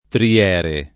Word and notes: triere: tri- $ re

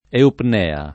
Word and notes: eupnea: italiana

